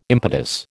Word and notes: impetus: American English

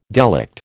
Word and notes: delict: American English

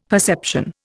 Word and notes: perception: Indian

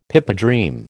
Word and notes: pipedream: American English

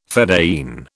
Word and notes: fedayeen: British English

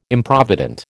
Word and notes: Improvident: American English

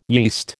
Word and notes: yeast: American English

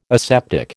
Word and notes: aseptic: American English

